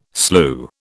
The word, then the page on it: slue: British English